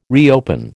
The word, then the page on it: reopen: American English